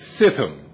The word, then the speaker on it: Shittim: And so back at Ambassador College, we were told to pronounce it Sithim.